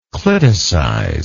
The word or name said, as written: cliticize